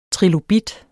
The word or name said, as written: trilobit